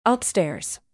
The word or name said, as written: upstairs